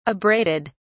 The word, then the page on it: abraded: British English